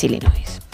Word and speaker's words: Illinois: nombre fonéticamente es Illinois. Así que Illinois y la S. Así que sin pronunciar la S final, Illinois, una vez más, tu apunte de geografía que nos viene muy bien, porque si no lo tuviésemos ya nos quedaríamos raros.